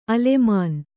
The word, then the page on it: ಅಲೇಮಾನಿ: Kannada